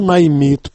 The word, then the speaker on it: наймит: По-русски слово «наймит» произносится с ударением на последнем слоге. Вы же, читая когда-то мое письмо, произнесли его по-украински, то есть с ударением на первом слоге.